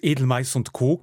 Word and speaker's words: Edelmais und Co.: Viele dürften Sie aus Edelmais und Co. kennen mit Betonung auf Co, wie Sie mir im Vorgespräch genau.